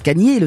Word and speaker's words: Kanye: Kanye West, car on prononce Kanye, le célèbre rappeur américain, a demandé à changer officiellement de nom pour s'appeler uniquement Ye, qui est la mm.